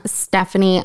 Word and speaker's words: Stephanie: And I am Stephanie.